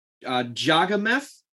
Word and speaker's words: Jagannath: One of them, I believe, is Jagannath. – You nailed it. That's exactly how it's pronounced.